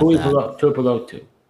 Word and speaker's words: Tuipulotu: Tuliopitu? I don't know how to say the last name. It's definitely not Tuipulotu.